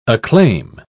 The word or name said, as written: acclaim